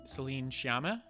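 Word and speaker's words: Céline Sciamma: So I feel like I would be cheating if I chose Celine Sciamma.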